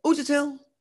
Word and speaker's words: Uchitel: Uchitel.